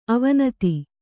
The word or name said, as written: ಅವಿನೀತ